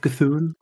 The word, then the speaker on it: Cthulhu: au milieu des statues de Cthulhu ou de Thun, je sais pas comment on le prononce. – Cthulhu, ouais. – Cthulhu.